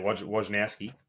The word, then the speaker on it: Wojnarowski: Written by, I forget his name, but the guy that does all the tweets, breaks all the announcements in NBA, Wojnarowski or however you say his name. – Wojnarowski.